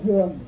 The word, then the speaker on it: dường: Rồi chữ dường á, nguyên chữ tàu đặt là dưỡng. Nó mình đọc là dường, thì cung dưỡng, cung là cung cấp, dưỡng là nuôi dưỡng, cung cấp và nuôi dưỡng, không có gì hết.